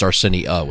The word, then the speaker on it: Arsenia: Calvin Arsenia, like Arsenio Hall. – Yeah, but instead of Arsenio, it's Arsenia with an A.